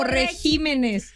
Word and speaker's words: regímenes: ¿Regímenes o regímenes? – Creemos que es regímenes, con el acento en la I.